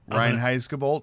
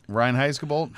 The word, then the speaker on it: Reinheitsgebot: Reinheitsgebot. – Right. Thank you. Say that again. – Reinheitsgebot.